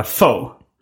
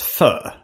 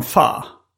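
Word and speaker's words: phở: Det stavas PH, PH eh, O med ett streck över. Mm. Och i USA är det många som uttalar det FO. Mm. Eh, min storebrorsa sa att det skulle uttalas fö.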